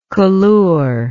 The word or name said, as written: colure